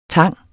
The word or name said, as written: tang